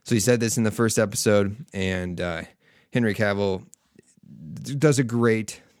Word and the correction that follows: Henry Cavill: I feel like I just mispronounced his name. Yeah, Henry Cavill.